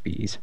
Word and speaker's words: B'z: This is Love Phantom by the Bees, which is B'Z. I've been told on good authority it's pronounced Bees.